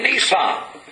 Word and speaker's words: Nisan: Nisan. N-I-S-A-N. That's what I call, the way I pronounce it.